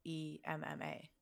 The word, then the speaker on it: Aemma: But Emma is cool too because it's spelled A E M M A. It's like Emma.